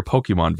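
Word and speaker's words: Pokémon: And it drives me absolutely insane that every single one of these commercials pronounce it Pokemon, because I know that's how it's actually supposed to be pronounced, because there's an accent on the E, but nobody I have ever met in real life pronounces it Pokemon.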